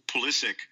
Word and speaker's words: Pulisic: the way it pronounces it is Pulisic, the 17-year-old at Dortmund.